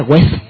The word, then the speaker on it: web: No se dice web, se dice web.